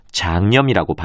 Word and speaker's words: 장염: 네, 이때는 연음에서 발음하는 간염과는 달리 니은을 첨가해서 장염이라고 발음합니다. 표준